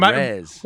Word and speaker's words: Perez: I mean, Perez.